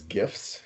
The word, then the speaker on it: GIF: Uh, the creator, Steve Wilhite, I'm guessing, says to pronounce it like a J, as in GIF.